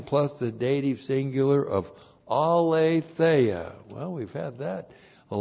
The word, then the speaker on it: aletheia: And then we have N plus the dative singular, which is the dative of sphere, in the sphere of aletheia. Aletheia, A-L, long E-T-H-E-I-A.